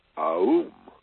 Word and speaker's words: AUM: but AUM is pronounced AUM, A-U-M. Why? Three parts, three sounds. It's the divine trinity again. In this case, the letter A, the letter U, and the letter M. And so when you chant verbally the AUM, it's A-U-M. Only put it together. A-U-M.